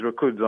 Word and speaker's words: Rakudzo: My name is Rakudzo. I'm calling from Dallas, Texas. – Rakudzo? Rakudzo? – Yes, actually, yes. That's Southern African. I'm from Zimbabwe. Yeah, and you pronounced it right.